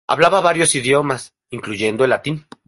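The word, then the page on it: idiomas: espanol; Spanish (Spain)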